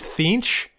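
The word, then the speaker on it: Tzeentch: It's T-Z-E-E-N-T-C-H. And I think it's Thinch. – Okay. – But it's hard to pronounce.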